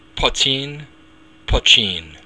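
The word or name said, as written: poitín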